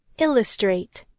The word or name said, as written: illustrate